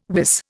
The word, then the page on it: wis: Indian